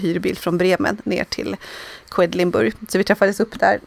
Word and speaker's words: Quedlinburg: Quedlinburg. Det är jättesvårt att uttala, det börjar liksom stavas med Q-U-E-D-L-I-N-burg, du förstår ju. Det går liksom inte att säga det på ett bra sätt, Quedlinburg.